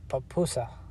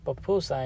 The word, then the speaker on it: pupusa: So it's pupusa, right? That's how you say it. It's pupusa, and if you eat with a side of salsa, man, it's good.